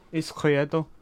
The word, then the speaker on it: Izquierdo: Izquierdo. – It's the correct Spanish. I'm doing the correct Spanish.